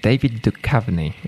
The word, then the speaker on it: David Duchovny: Możecie usłyszeć, jak prawidłowo się to mówi. David Duchovny. Tak to powiedział ten speaker na Fox. On chyba wie.